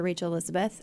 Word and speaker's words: Rachael Elizabeth: So they can find me on Instagram or on Facebook at Dr. Rachel Elizabeth. – Okay. – And my name is spelled R-A-C-H-A-E-L. So just make sure you put that in there. And it's Elizabeth with a Z.